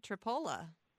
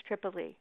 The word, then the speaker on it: Tripoli: And don't forget Tripola. Tripola. It's spelled exactly like Tripoli. – And it's pronounced Tripola? That I didn't know. I thought it was Tripoli.